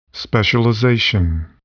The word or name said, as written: specialization